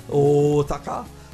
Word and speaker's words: Osaka: It's like the mm-hmm. – hard O, right? – Overpronounce it, yes. – Osaka.